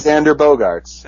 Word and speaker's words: Xander Bogaerts: Now, in terms of specific players, it seems like one we're contractually obligated to talk about, if none other, is Xander Bogaerts. Am I saying that correctly? – Yes, it is Xander Bogaerts.